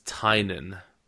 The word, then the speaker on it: Tynan: This comes from an interview I did with a man whose name we've been mispronouncing for a very long time now. It's apparently James Tynan.